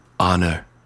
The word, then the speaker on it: honor: There are two main reasons that H is not pronounced in an English word. One, derivative. This happens when a word derives from a language in which H is silenced, including, like, honor. It's not honor.